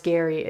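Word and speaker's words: Gerry: If you're tuning into The Golden Bachelor coming up soon, it's Gary. It looks like it's spelled Jerry, but The Golden Bachelor, his name is Gary.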